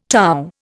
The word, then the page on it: torr: Indian